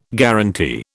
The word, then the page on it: guaranty: British English